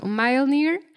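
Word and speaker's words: Mjölnir: Eh, pues no sé si yo lo pronuncie bien, pero es Mjolnir. – Ajá, exacto. Mjolnir pero hay toda una broma existente que la gente dice mew mew para el personaje de, Darcy.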